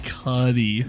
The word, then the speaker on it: Cudi: Pronounced Cudi. – Yes. – Definitely was thinking Cudi. – No, nope, that's not right. – Like cooties. – No, not like cooties.